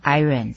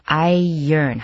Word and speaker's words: iron: iron, 你大概会念作 iron, 这是不对的哦，念作 iron, 好像那个 O 跟 R 颠倒过来那种发音一样，念作 iron, 不要念作 iron, OK?、嗯 Onto the next sentence.